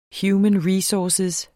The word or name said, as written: human